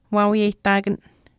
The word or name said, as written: waawiyehtaakan